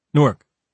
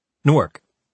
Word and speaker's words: Newark: He pronounced the city name very fast and with no accented syllables. Newark. I'd grown up near Newark, Delaware, which we called Newark in our hybrid tri-state dialect, a tic that Rob considered a sacrilege.